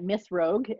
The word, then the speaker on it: Miss Rogue: My name is Tara Hunt, but you can find me at Miss Rogue everywhere.